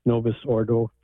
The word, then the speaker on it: Novus Ordo: And I believe it's because they think it's more reverent than the Novus Ordo, if I pronounce that right.